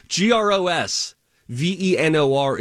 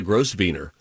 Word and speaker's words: Grosvenor: G R O S. V E N O R is the Grosvenor, not the Gross Wiener.